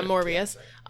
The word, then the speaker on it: Morbius: Yeah, Morbius.